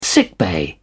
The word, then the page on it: sickbay: British English